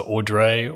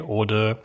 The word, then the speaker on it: ORDRE: It's French for the order of things. So ORDRE, ORDRE.